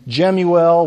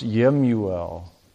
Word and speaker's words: Jemuel: And in verse 15 of the text, there's this name Jemuel, which is probably more accurately pronounced Yemuel.